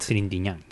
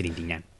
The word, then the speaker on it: Trintignant: Y está, por supuesto, Jean-Louis, a ver si lo puedo pronunciar bien, Trintignant. – Trintignant. – Trintignant. – Sí. Trintignant. Con ñ. Trintignant.